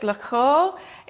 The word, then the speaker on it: Loughor: Now, I don't speak Welsh, so I'm sorry if I butcher this, but it's Lugor. It's spelled L-O-U-G-H-O-R, and that was a little village that he lived in.